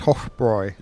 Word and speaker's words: Hofbräu: So it's pronounced Hofbräu.